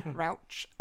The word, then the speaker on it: Rauch: Melissa Rauch, or Rauch, I actually don't know how to say her last Ranch. – Name. Rauch.